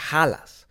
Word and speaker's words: Hällas: Creo yo que se pronuncia Halas. Porque se escribe H-A con diéresis, doble L ese.